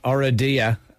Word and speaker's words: Oradea: So talk to us about the competition then that you did in Oradea. In Romania, I don't know if I'm pronouncing that right. – Oh, sounded perfect. – Sounded like a local.